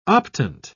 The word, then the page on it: optant: British English